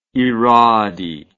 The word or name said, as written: irade